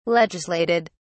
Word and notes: legislated: British English

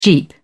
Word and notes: jeep: British English

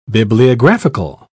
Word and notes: bibliographical: British English

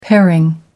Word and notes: paring: American English

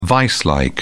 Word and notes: viselike: British English

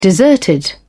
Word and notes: deserted: British English

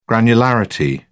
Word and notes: granularity: British English

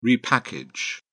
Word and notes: repackage: British English

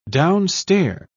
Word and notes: downstair: British English

